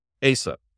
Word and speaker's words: ASA: How do you pronounce it? – ASA. – ASA.